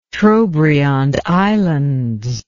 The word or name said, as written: Trobriand Islands